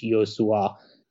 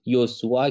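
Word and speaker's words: Joshua: شما آلمانی بلد نیستی سال نظر نکن اولا که یه مصاحبه داره کیمیش چون که اسم کوچیکش یوسوا تلفظش توی آلمانی هم عجیب غریبه یعنی اسمی که شنیده نشده ازش پرسیدن اسمش تلفظ کنه اون مصاحبه موجوده تلفظ اسمش رو از زبون خودش میتونی بشنوی یوسوا کیمیش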